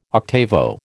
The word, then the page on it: octavo: American English